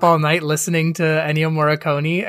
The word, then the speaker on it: Morricone: Morricone, yeah, that guy.